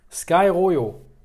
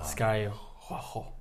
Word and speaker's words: Sky Rojo: Sky Royo. Sky Royo. Ja, halt, wird bestimmt anders ausgesprochen. Ja. Sky Rojo. – Rojo. Rojo.